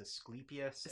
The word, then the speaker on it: Asclepius: I might have pronounced that god's name wrong. I probably almost certainly did. But what is his name? – His name is Asclepius, and they are Asclepiad temples.